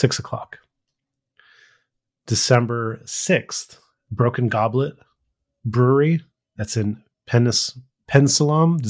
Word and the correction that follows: Bensalem: Is that how you say it? – Bensalem.